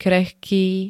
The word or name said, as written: krehký